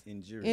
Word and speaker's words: injurious: Injurious. – Okay. Spell that word? – It's like injury. Us. – Injurious.